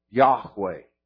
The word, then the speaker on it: Yahweh: Is actually the covenant name of God, which is, best as we can understand, would be pronounced Yahweh. And you got to kind of have a guttural Yahweh.